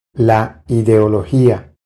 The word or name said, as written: La ideologia